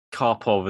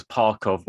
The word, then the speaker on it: Karpov: Okay, so my favorite game is so we we have I can't remember how it came about, but I think John mispronounced Karpov as Parkov once, and it just became this thing.